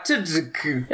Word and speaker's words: Tudyk: The first one is that I felt, even though Tyler Labine, I always call him Tuck, but I guess that might be wrong. How did you say it? – Oh, Alan Tudyk? – Yeah. I guess I called him Tudyk. Tuck? – You called him Tuck? Yeah, Tudyk.